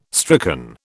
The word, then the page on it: stricken: British English